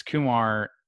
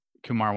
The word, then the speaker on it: Kumar: and actually I should say that apparently Kumar wants to go by Kumar Rocker instead of Kumar. I know I've been guilty of saying Kumar, but apparently Kumar is the appropriate pronunciation.